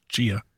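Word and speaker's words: Tchia: I don't know why I always call it Tachia because there's a giant T in front of it, but I think it's pronounced Chia.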